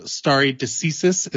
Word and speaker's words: stare decisis: And stare decisis, is that how I pronounce it correctly? – Stare decisis.